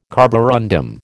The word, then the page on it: carborundum: American English